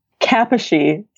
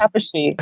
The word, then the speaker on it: Kaposi: And the answer for this one is B, human herpes virus 8, HHV-8, because this patient has Kaposi sarcoma.